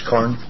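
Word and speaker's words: Carne: Madam Chair, members of the committee, my name is Bruce Karn, Captain of the Fish and Wildlife Division.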